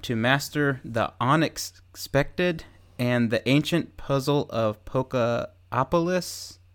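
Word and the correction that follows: Pokémopolis: Did I say that right? – Pokemopolis. – Okay.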